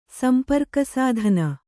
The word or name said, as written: ಸಂಪರ್ಕಸಾಧನ